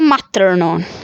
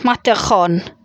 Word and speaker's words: Matterhorn: Allora, il Cervino in francese si pronuncia Servin, Servin e in tedesco Matterhorn. Matterhorn.